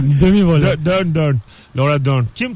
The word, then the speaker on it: Laura Dern: E, Mark Hamill, e, Carrie Fisher, Laura Dam, Oscar Isaac. Yani, Laura Dern mi yoksa o ya? He, olabilir. RN mi o?